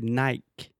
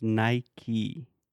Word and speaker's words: Nike: Uh, is obviously phonetically Brazilians should say Nike, Nike, but for some reason Brazilians decided to say Nike. But in English, it's Nike. Nike.